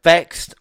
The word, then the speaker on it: VXT: Vexed, or VXT as it's spelled, Deanna Pratt with Chelsea Green defeat Knockouts World Champion Jordan Grace and Mia Yim.